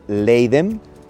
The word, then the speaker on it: Leiden: Leiden, más o menos la pronunciación en español, Leiden.